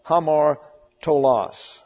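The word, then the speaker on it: Hamartolos (ἁμαρτωλός): It is the accusative masculine plural of hamartolos or hamartolos. As your rough breathing, H-A-M-A-R-T-O, there's an omega L-O-S, and that's an omicron. Hamartolos, accent down here on the last syllable.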